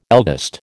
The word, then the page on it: eldest: American English